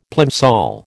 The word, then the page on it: plimsoll: American English